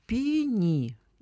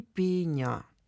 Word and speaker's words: пеня: Обычно это слово используется все-таки в форме множественного числа, но если уж зачем-то нужно, то словари позволяют говорить «пеня», то есть в единственном числе ударение падает на первый слог. И очень старая форма «пеня», но она считается сейчас уже не вышедшей из употребления и ошибкой.